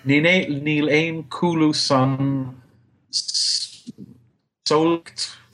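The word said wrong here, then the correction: samhlaíocht: Sauliacht. How do you pronounce it? Sauliacht.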